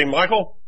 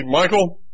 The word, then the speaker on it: Mikell: And he'd say to me, occasionally he'd say, Michael, because my middle name, I go by my middle name, and it's spelled M-I-K-E-L-L. People say it's a different spelling.